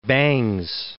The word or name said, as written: Bangs